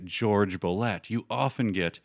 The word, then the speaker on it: Jorge Bolet: There's a piano player named George Bolet, but it's spelled Jorge Bolet. But he wanted his name to be pronounced, anglicized, as George Bolet.